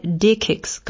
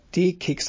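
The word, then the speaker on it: DE-CIX: Ja, da bist du bei D-Kicks gelandet. – Genau, D-Kicks, das muss man auch mal, das heißt, sie werden anders geschrieben, C-E-X, also D-E und C-I-X, aber Kicks ausgesprochen.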